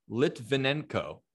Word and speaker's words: Litvinenko: And uh, this guy who is named Alexander uh, Litvinenko, and I want to say his name right at least. You got it.